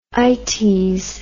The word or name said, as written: ites